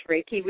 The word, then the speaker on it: Reiki: You learned Reiki. Am I saying it right? The intense. – Yes, Reiki, which is an energy healing.